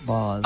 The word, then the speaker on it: Baz: You know, I hate to tell you this, but he does pronounce it Boz. – Oh, boo! – Yeah, well, thanks for telling me that. – He says it. He spells it the same way.